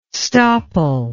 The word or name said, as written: stopple